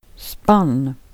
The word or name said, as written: hästspann